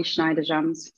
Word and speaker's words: Schniederjans: Ollie Schniederjans.